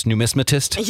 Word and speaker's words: numismatist: Are you happy I could pronounce numismatist? – You did it.